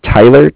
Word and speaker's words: Tyler: My name is Tyler, T-Y-L-E-R. And I'm calling with Cardiff. It's spelled C-A-R-D-I-F-F, as in Frank Frank.